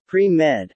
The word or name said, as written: pre-med